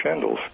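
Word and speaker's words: Schandl: Well, Dr. Shandl's cancer screening is available. And, can you spell that? – It's S-C-H-A-N-D-L. – Shandl. – Yeah, Emil, E-M-I-L, Shandl.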